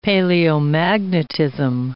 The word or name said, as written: paleomagnetism